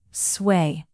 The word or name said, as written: sway